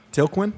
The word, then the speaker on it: Tilquin: how do you say it? – Tilquin? – Tilquin, thank you.